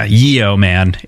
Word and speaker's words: yeoman: Yeo man is how you would spell it, but it is pronounced yeoman in the same way.